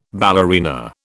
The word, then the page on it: ballerina: British English